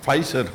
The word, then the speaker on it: Pfizer: Pfizer.